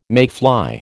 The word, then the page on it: mayfly: American English